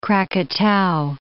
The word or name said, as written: Krakatau